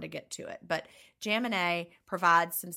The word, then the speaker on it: Jaminet: Paul Jaminet. He is the Perfect Health Diet. – Jaminet. I knew I was saying it wrong.